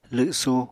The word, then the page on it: lwsu: apatani